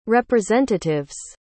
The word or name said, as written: representative's